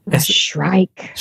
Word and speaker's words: Shrike: Do do do the sh- it's Shrike? Is that how we pronounce it? – Shrike. – Shrike. So it's like Shrek, but it's Shrike.